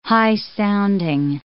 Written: high-sounding

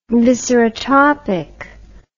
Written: viscerotropic

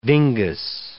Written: dingus

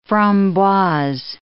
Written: framboise

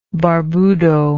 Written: barbudo

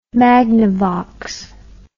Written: magnavox

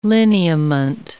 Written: lineament

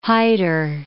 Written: hider

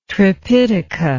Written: tripitaka

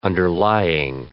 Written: underlying

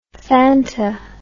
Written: Fanta

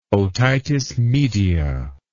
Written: otitis media

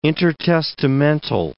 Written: intertestamental

